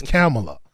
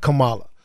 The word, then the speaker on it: Kamala: It's Kamala, not Kamala.